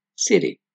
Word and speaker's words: city: La palabra ciudad, por ejemplo, en inglés, city, se pronuncia muchas veces como city, en New York City.